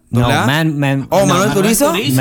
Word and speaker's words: Manuel Turizo: Manuel, how you say it? – Anuel. – Manuel Turizo. – Manuel Turizo. I think so.